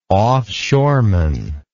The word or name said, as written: offshoreman